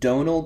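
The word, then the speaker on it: Domhnall: And you cannot escape the magic of, I think it's pronounced Donal Gleason.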